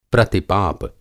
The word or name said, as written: प्रतिपाप